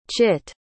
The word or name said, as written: chitt